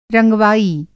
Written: रंगवाई

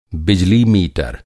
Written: मीटर

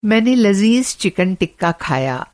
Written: लज़ीज़

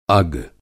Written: अग

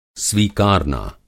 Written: स्वीकारना

